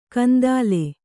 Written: ಕೆಂದಲೆ

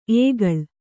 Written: ಎಕ್ಕಲ

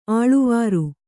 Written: ಅಳವರಿ

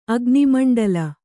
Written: ಅಗ್ನಿಮಂಡಲ

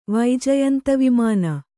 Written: ವೈಜಯಂತೀವಿಮಾನ

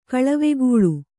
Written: ಕಳವೆಗೂಳು

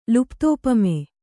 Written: ಲುಪ್ತೋಪಮೆ

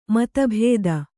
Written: ಮತಭೇದ

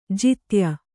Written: ಜೊತ್ತಾಯ

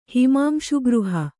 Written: ಹಿಮಾಂಶುಗೃಹ

